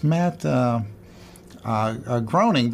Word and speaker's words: Matt Groening: I said, it's Matt Groening because Walter König told me.